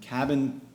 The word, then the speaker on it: KABN: Moving forward, we also have Cabin, and Cabin, K-A-B-N is how that's spelled, is launching an ERC-1400 equity token.